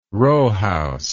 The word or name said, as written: rowhouse